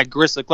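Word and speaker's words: Grzelcyk: Is it Grizzlick? Is that how you pronounce Grizzlick? – His name? – Yeah, Grizzlick.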